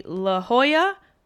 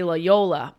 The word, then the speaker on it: La Jolla: I will be at the La Jolla Comedy Store. Wow, I can't believe I actually pronounced it right. I wanted to say La Jolla, but that is completely incorrect.